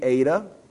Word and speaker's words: eta: The long E, or pronounced like in the word late, long A sound, is the eta.